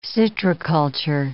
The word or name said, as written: citriculture